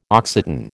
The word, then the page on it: Occitan: American English